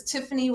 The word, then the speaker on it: Tiffanie: because Tiffany with an IE is unusual.